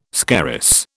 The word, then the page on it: scarus: British English